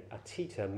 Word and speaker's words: atita: Atita, A-T, long I-T-A, atita means beyond.